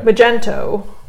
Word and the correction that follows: Magneto: Typically pronounced as Magneto.